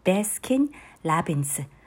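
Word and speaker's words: ベ ス キ ン・ ラ ビ ン ス (배스킨라빈스): バスキン・ロビンスを韓国式に発音してベスキン・ラビンスベスキン・ラビンスこれをまた略して、えー、頭文字を取って、えー、ベラベラというふうに呼ばれます。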